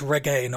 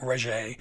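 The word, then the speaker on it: Regé-Jean: And we mispronounced his first name completely last time. It's Reggae, not Regé.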